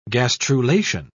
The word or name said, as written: gastrulation